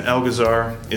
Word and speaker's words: Elgazar: An excellent pronunciation of my last name. You didn't even ask. I don't even think it's that hard. – Sometimes I'm bad about just rolling with it, and I'm pretty good at it. – Elgazar, I feel like it's just the way it's spelled.